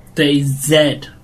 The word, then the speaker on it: DayZ: DayZ.